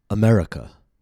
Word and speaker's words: America: For example, America. 4 syllables. Notice how the letter A is reduced. America. A common mistake might be America. A instead of but a native speaker will say America.